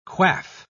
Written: quaff

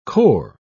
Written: core

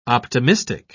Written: optimistic

